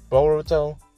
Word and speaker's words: Boruto: Like, Boruto.